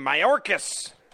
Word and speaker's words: Mayorkas: Ms. Producer, how do we say his last name? Mayorkas. I got it right.